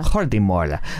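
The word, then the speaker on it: Jordi Mollà: Jordi Molla.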